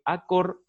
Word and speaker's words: Acord: Voy ahora al ejemplo de Acor Salud. Acor se escribe A-C-O-R-D Salud.